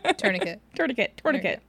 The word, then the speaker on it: tourniquet: Tourniquet. It's just tourniquet.